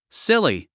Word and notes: silly: síli; スィリィ

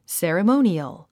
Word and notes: ceremonial: sèrəmóuniəl; セラモゥニアル

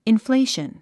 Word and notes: inflation: infléiʃən; インフレイション

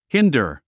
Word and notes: hinder: híndər; ヒンダァ

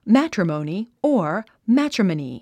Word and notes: matrimony: mǽtrəmòuni; マァトロモニィ

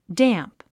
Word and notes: damp: dǽmp; ダァンプ

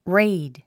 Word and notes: raid: réid; レェイド

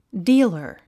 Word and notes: dealer: díːlə r; ディーラァ

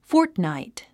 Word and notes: fortnight: fɔ́ːrtnàit; フォートナイト

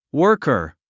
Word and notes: worker: wə’ː r kə r; ワーカー

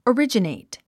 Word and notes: originate: ərídʒənèit; オリジネェイト

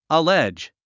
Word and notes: allege: əlédʒ; アレジ

